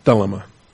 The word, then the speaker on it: thelema: According to the eutychia of his thelema, or thelema, put the accent on the first syllable there.